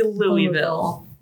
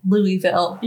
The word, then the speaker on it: Louisville: You say Louisville. – Louisville. – You're still not saying it right.